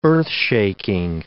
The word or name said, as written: earthshaking